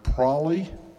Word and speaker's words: prolly: If you hear someone using the word prolly, P-R-O-L-L-Y, rhyming with trolley, that, which is, I think, mostly in eastern Texas, I'm not sure, that is, in other states, is pronounced probably.